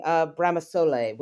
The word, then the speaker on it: Bramasole: For those who don't know, she is the writer of Under the Tuscan Sun, and you talk about visiting Bramasole, which I'm assuming is am I pronouncing that correctly? – Correct, perfect.